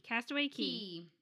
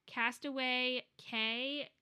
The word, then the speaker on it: Castaway Cay: I'm like, I understand that it's pronounced Key, but like, Castaway K sounds way better than Castaway Key.